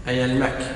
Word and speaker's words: أيلمك: قال أخبرنا أبو الخطاب عمر بن أيلمك هذا النطق الصحيح أيلمك، نعم كلمة فارسية.